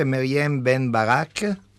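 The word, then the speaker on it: Meryem Benm'Barek: E adesso diamo il benvenuto alla nostra ospite di oggi, spero di pronunciare il nome correttamente, Maryam Ben Barak. Ok? Lei eh, dice di sì. Ha fatto segno col pollice che va bene.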